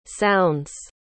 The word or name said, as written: sounds